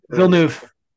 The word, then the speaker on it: Villeneuve: Denis Villeneuve. – Villeneuve.